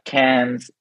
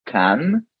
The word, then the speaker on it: Cannes: Sorry, it's not Cannes, but Cannes. It's not Paris, but Paris. – So why is it Cannes and not Cannes? – It's Cannes, actually. It's not Cannes.